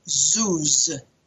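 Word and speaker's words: zuz (זוז): But it derives from a very interesting Hebrew root, sometimes pronounced zuz. Zuz, Z-O-O-Z would be spelling it phonetically, zuz, which means to move back and forth.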